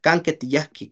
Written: kanketiyajki

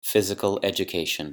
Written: physical education

